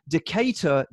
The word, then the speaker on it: Decatur: Gentlemen, I write to you somewhat inspired by the email read during last week's pod by fellow Decatur, Georgia resident, and that is how you pronounce it apparently, Doug Wood, but mostly inspired by Chinch's story about how Howard Kendall would wear a Speedo on the coach.